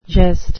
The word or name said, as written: gest